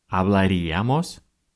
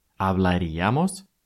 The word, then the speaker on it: hablaríamos: Не «hablaríamos», как вначале может захотеться, а именно «hablaríamos». Помните, всегда ударение будет на «ия».